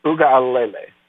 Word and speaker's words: Uiagalelei: DJ Uiagalelei is the is the uh, the way you pronounce it.